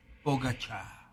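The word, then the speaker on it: Pogačar: La pronunciación correcta de Pogacar es Pogacha.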